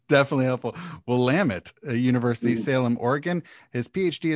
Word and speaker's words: Willamette: Did I pronounce that correctly? Willamette? – Willamette University. – Willamette. Ooh, I was not going that direction. – Willamette. Oh, they have a saying. Yeah. It's Willamette, damn it.